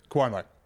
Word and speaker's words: Cuomo: So, Kumo, you're saying? Cuomo. Cuomo, you're saying.